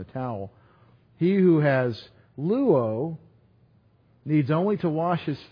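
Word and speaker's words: luo (λούω): Jesus said to him, "He who has luo," different word, L-O-U-O, L-O-U, long O.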